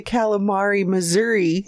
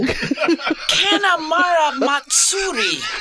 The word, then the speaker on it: Kanemaru Matsuri: I thought you said the Calamari, Missouri. – No! Kanemaru Matsuri.